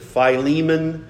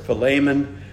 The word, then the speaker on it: Philemon: Some people pronounce it Philemon. I pronounce it Philemon.